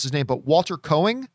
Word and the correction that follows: Koenig: is that how you pronounce Chekhov's name? – Walter anyway. – Koenig. Koenig. Okay. – Yeah. Koenig.